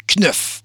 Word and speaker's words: Knuth: Euh, comment qu'on prononce? Écoute, ça s'écrit K-N-U-T-H. Knuff.